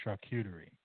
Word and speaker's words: charcuterie: So it's charcuterie? Cootie? Like cootie? – Yes. – I would say in the English pronunciation is charcuterie. That's acceptable.